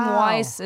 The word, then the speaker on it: Wajs: The name Weiss is pronounced the same as the watchmaker Albert Weiss. – If it's pronounced the same. – I know, sorry. It confused me because it was spelled W-A-J-S.